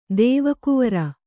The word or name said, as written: ದೇವಕುವರ